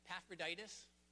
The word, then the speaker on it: Epaphroditus: And we read about Epaphroditus, five syllables long, nice long name, in Paul's letters to the Philippians, as also the Colossians and Philemon.